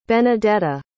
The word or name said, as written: benedetta